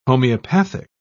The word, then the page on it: homeopathic: British English